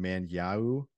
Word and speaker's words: Manyawu: Manyau, I think is how you say it. M-A-N-Y-A-W-U.